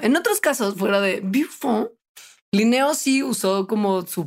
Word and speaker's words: Buffon: Me da mucha risa que mi maestra de historia y filosofía de la biología en la carrera, primer semestre, insistía muchísimo en pronunciar el nombre de Buffon correctamente. Entonces, cada vez que hablaba de Buffon decía Buffon. Y era como, señora, puede decir Buffon, no pasa nada.